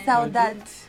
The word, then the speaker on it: saudade: E se avete saudade contro Brasile o contro qualcos'altro? – Innanzitutto come si pronuncia? Com'è la pronuncia giusta? – Saudades. Singolare saudade, plurale saudades. Saudades.